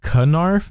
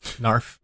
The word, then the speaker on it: Narf: But people were like, is it Knarf? And I'm like, no, like the K is silent, like in knife. – Narf.